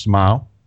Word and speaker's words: Smile: Looking at this, I think the Smile is pronounced Smile, as in a smile.